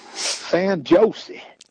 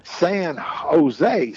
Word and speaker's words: San Jose: He said, I'm headed down to San Jose. He said, San Jose? Oh, said, you mean San Jose? Said, out here, says, the J's are pronounced like a H.